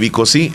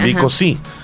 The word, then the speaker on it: Vico C: Entonces, la película de Vico Sí es la historia de él. Y se escribe Vico C. Así, Vico C. Y con V. – Vico Sí.